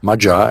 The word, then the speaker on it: Magyar: There appears to have been a worldwide root language, which was Magyar, as it's pronounced properly, which is related from Hungarian.